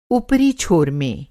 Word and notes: छोर: چھور

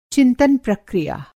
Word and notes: चिंतन प्रक्रिया: Hindi

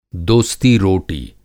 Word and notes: दोस्तीरोटी: dosti roti

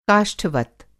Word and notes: काष्ठवत्: Hindi

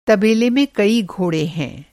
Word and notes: तबेला: masculine noun

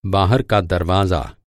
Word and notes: बाहर: masculine noun

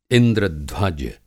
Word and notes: इंद्र-ध्वज: Hindi

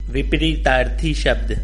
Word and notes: विपरीतार्थक: Hindi

